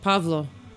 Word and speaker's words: Pavlo: P-A-V-L-O, Pablo. It works. – Mm-hmm. – Pavlo? – P-A-V-L-O. – Yeah, that makes more sense.